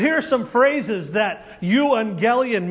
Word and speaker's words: euangelion: It looks like the word evangelist, doesn't it? But it's pronounced euangelion.